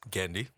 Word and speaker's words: Genndy: Genndy. – Sorry. – If you're a big fan, you should at least call and say his name correctly. – Well, I mean, I pronounce it in English.